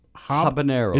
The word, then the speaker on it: habanero: Barbecue, honey garlic, Asian sesame, sweet buffalo, original, firecracker, hot, spicy roasted garlic, new mango habanero. – Is it so the H, you do pronounce it.